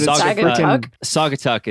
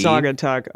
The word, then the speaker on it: Saugatuck: It looks like Saginaw Tuck. I don't know. – But Saugatuck is usually how they pronounce that, I believe. – Saugatuck.